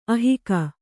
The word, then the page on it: ಅಹಗೆ: ahage